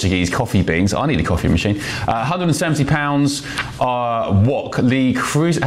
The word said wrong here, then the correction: Le Creuset: how do you say that? Le Creuset wok, and crucially with a lid. Creuset.